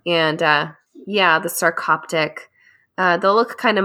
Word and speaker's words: sarcoptic: Sarcoptic. – Sarcoptic.